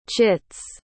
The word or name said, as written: chits